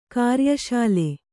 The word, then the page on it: ಕಾರ್ಯಶಾಲೆ: kāryaśale